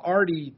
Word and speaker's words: Aethari: Artie spelled A-E-T-H-A-R-I, but still pronounced Artie.